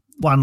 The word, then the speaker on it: Joan: Look, we brought Juan Luque, or Joan, as it's spelled.